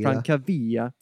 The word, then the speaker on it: Francavilla: How do you say that, really? – Francavilla. – Took